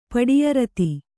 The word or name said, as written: ಪಡಿಯರತಿ